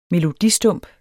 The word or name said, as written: melodistump